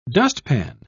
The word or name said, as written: dustpan